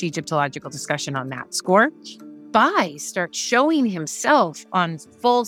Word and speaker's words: Bay: B A Y. So it looks like Bay, but you pronounce it Bai.